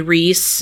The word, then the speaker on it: Rhys: It's Rise Bowen, R H Y S. Oh, Reese. – Um, maybe Reese. Reese. Reese Bowen.